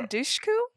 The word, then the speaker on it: Dushku: This movie stars Kirsten Dunst, Gabrielle Union, Eliza Dushku.